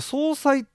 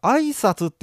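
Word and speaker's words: 相殺: これ総裁って読むんだよね、うん、なんか挨拶って呼んでる人がいま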